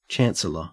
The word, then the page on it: chancellor: Australian English